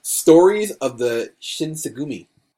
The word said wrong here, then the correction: Shinsengumi: I'm saying that wrong, too. – Shinsengumi.